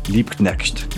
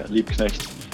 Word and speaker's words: Liebknecht: Is it Liebknecht? Barcelona? Liebknecht. There you go. – Yeah, Liebknecht.